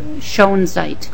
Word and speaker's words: Schoenzeit: Schoenzeit. – Could you also spell that for the record, please? – S-C-H-O-E-N-Z-E-I-T.